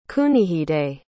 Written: Kunihide

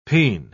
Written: peen

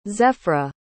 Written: zephyra

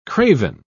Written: craven

